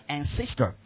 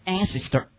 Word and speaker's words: ancestor: OK，祖先称为 ancestor，注意重音，不要往上挑啊，不要读成 ancestor。 ancestor。